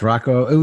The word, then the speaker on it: Rocco: Yeah, it's Rocco.